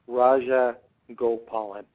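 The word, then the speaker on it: Rajagopalan: Raja Gopalan. So it's broken in syllables.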